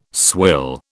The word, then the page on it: swill: British English